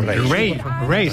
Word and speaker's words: Raye: Ray. Se escribe Raye.